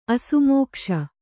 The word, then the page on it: ಅಸಮಕಸಿ: asamakasi